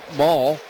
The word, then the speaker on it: Moll: So maybe a chance for more magic from Jimmy Crooks against the lefty Maul, who spells his name M-O-L-L, but pronounces it like the mall, like you go to the mall.